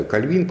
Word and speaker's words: Кальвин: Вообще правильно я считаю Кальвин, поскольку он все-таки говорил, писал на французском языке, ну а во Франции, вы знаете, ударение ставится на последнем слоге.